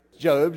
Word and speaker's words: Job: I mean, you thought it was Job. It's spelled the same way, but Job is just kind of how you pronounce it.